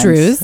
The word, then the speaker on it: Druze: It's Drew's. – It's like, this is wordplay. – Yes, and Drew's is spelled, in case you want to look it up, D-R-U-S-E or D-R-U-Z-E, and it's the only Drew's restaurant in all of New York City.